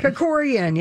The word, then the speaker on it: Kerkorian: Dollars for stealing the dental floss to figure out that he had fathered a child with Kerkorian. Am I saying his name right? – Kerkorian, yeah.